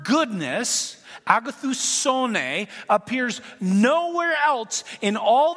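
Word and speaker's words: agathousone (ἀγαθωσύνη): And this word goodness in Greek is agathousone. Agathousone, that's what it is. Emphasis on the so.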